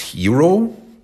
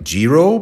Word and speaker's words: xhiro: It's spelled X-H-I-R-O. It's pronounced Hiro, but not like Giro, because Giro is the incorrect pronunciation of what is actually a Hiro.